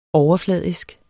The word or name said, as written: overfladisk